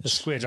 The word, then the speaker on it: Squeege: Give us the proper pronunciation of your last name. – Squeege. – Squeege, okay. – Squeege. That sounds classy. – Yeah. – Squeege. – Right. Sounds French.